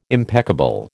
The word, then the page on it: impeccable: American English